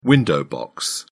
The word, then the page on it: window box: British English